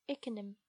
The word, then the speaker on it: Ickenham: Ickenham. – If Ickenham.